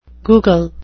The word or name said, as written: Google